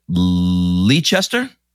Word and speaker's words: Leicester: I think I'm going to say it right. Leicester. Leicester. Close. Leicester.